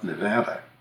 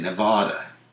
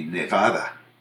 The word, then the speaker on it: Nevada: But broadcasters from the East, for the most part, except a very few who get clued in, say Nevada. And it just immediately identifies a reporter as being from not here. – Mm-hmm. – When they use that pronunciation. When they use that pronunciation. Of course, there's an irony involved. If you took it to be a Spanish word, which it is, meaning snowy, it would be Nevada. So the Eastern pronunciation would be closer to the Spanish, but it's still not the way that it's pronounced by most people in the West.